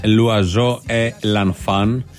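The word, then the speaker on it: L'Oiseau et l'Enfant: Το άλλο που ακούγαμε που λεγόταν Λουαζό Ε Λανφάν, έτσι προφέρεται, σημαίνει το πουλί και το παιδί.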